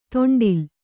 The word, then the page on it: ತೊಂಡಿಲ್: to'ṇḍil